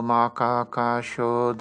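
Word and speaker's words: Makakasho (摩訶迦葉): It begins with his immediate successor, Mahakashyapa, here rendered in the Japanese pronunciation. Shakyamuni Butsu Dayosho Makakasho Dayosho Ananda Dayosho.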